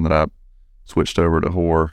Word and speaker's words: Hoar: Hoar Construction, it's pronounced Hoar, and it is spelled H-O-A-R.